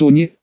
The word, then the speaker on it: они: Начнем с того, что классификация их начинается с они, ударение на первое, а так вот они, это демоны-людоеды, часто, но не всегда, отличаются крупными размерами.